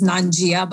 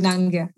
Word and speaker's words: Nangia: And the last name is Nangia, which most people pronounce as Nangia, but it's incorrect. So it's Deepali Nangia.